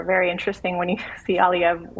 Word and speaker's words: Aliyev: The president of Azerbaijan, Aliyev, is that how you say it? – Aliyev.